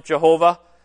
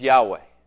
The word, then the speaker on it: Yahweh: I don't think it's bad to pronounce it Jehovah, as long as we understand what it goes back to. But the way a Hebrew speaker today would pronounce it is Yahweh.